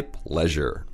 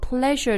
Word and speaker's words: pleasure: Right, so the first one here is,It's my pleasure. – It's my pleasure.、Mm. 就是那个 it was a pleasure meeting you. – Yeah, same pleasure. – 哦，前两天我还看到朋友留言问我们说 pleasure 的发音。 – It's not pleasure. Pleasure. – Pleasure. 那位朋友我记得他是不太会发后面 S-U-R-E.